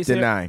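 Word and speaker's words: Danai: Danny? – How you say Deny.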